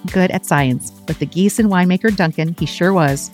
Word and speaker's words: Giesen: It's Giessen, spelled G-I-E-S-E-N. Sounds like the geese that maybe you see at the park.